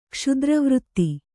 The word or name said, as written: ಕ್ಷತ್ರವೃತ್ತಿ